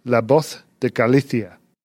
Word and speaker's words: La Voz de Galicia: It was La Voz de Galicia.